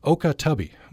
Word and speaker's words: Okah Tubbee: Okah Tubbee.